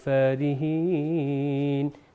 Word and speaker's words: فارحين: تو یہ ہوگا وتنحتون من الجبال بيوتا فارحين. فارحین فا کریں گے تو اس کا مطلب ہے اس کا تلفظ غلط ہو جائے گا.